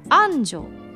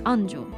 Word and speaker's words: 安城: 一般的には前側を強く発音していますが今ねすごくそこのところが太文字になっていたのであの「岡」と「あん」と「ち」と「に」がねわざと岡崎安城知立西尾って読んだ見たんですが地元では平坦に読んでいるんです。